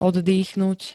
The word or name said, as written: oddýchnuť